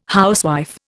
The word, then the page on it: housewife: Indian